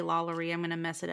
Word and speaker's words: LaLaurie: And, you know, I think I was saying the name wrong because it's spelled LaLori. But I think because she's Spanish. – It's French. Is it French? Is her husband French? – That's why I get confused. Because then I was like, is it like La Laurie? – I think that's right. Because I think if I remember hearing it correctly in like the American Horror Story, I think it's La Laurie.